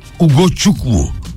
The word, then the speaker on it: Ugochukwu: Leslie Ugochukwu bu arada okunuş arkadaşlar. Ugochukwu.